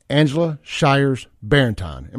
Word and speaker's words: Angela Shiers Barrentine: He is a private investigator working on this case for the family of Miss Angela Shiers Barrentine. Am I saying her name right? – You are. Yes, Clay.